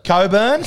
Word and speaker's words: Cockburn: Coburn. – Spelled Cockburn. At least they fucking pronounce it differently.